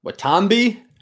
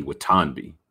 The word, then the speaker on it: Watanabe: Watanabe? How do you spell that? – W-A-T-A-N-B-E, Watanabe.